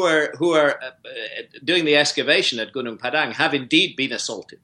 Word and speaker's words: Gunung Padang: Did I read it wrong that you were almost attacked in Gunung Padang? Did I say that right? – Gunung Padang in Indonesia.